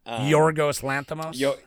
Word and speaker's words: Yorgos Lanthimos: Yorgos Lanthimos.